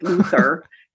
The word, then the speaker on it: Luther: And it almost sounds like we're saying loser, but it's not. It's Luther.